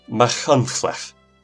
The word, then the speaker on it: Machynlleth: Machynlleth.